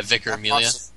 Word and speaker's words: Vicar Amelia: Yeah, Vicar Amelia.